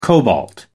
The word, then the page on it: cobalt: American English